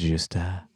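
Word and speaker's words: Gjusta: I think the G is silent. It's just Gajusta.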